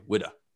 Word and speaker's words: Whydah: They spell it differently. The ship is spelled Whydah, and the town is spelled Ouida, but it's pronounced the same way, Whydah.